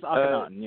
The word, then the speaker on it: Akhenaten: Akhenaten Warrior. Am I saying that right? – I'm sorry, which one? Oh, Prince Akhenaten, yes.